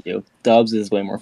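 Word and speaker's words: Doubs: from Romeo Dubs, it's looked pretty good so far. – I think it's pronounced Dubs, right? – Yes, it is Dubs, sorry.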